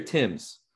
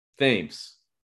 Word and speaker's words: Thames: Is it Thames or Tim's? – Thames. – Because our colleague at Five Tool, Nick, is Nick Timms, and it's spelled the same way, like the river in England.